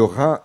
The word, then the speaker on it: Rhins: Le Rhin, c'est le nom d'une rivière, et le Rhin, ici, cela s'écrit R-H-I-N-S, on ne prononce pas le S à la fin.